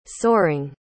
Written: soaring

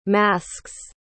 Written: masks

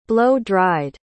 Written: blow-dried

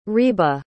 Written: ryba

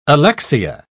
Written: alexia

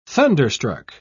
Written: thunderstruck

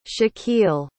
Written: shaquille

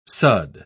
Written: sudd

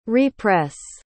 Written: re-press

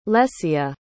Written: lesya

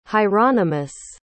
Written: hieronymus

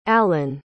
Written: allan

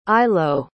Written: ilo

